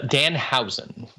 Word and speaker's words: Danhausen: It's a Danhausen.